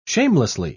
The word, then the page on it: shamelessly: British English